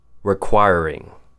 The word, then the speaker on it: requiring: The first word, requiring. Break it down into four syllables. Requiring. Requiring. Requiring. Next word, deterrent. D has the long E sound, ter as in T-E-R, and unt.